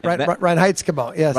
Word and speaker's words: Reinheitsgebot: Reinheitsgebot, yes.